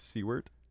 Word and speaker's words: Siewert: Did you say my name was Zac Siewert? – Isn't that how you pronounce your last name? – It's not that. – What is it, Henry? He knows. – It's Siewert.